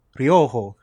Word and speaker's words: Ryoho: Am I saying it right? – Yeah. It's Ryoho? – Ryoho. – Ryoho. – Yeah.